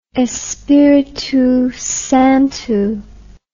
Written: espiritusanto

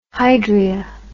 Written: hydria

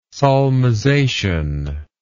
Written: solmization

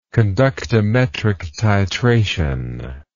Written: conductometric titration

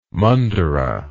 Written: mandira

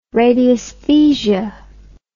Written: radiesthesia

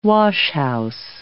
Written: washhouse

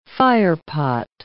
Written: firepot

